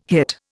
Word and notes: hit: Indian

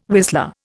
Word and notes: whistler: Indian

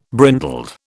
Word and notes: brindled: British English

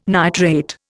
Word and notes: nitrate: Indian

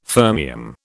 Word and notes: fermium: British English

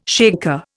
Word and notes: shaker: Indian

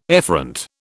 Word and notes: efferent: British English

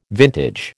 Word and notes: vintage: American English

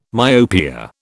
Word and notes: myopia: British English

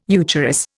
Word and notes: eucharis: Indian

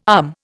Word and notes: um: Indian

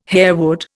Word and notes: harewood: Indian

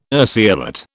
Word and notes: urceolate: British English

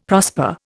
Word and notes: prosper: Indian

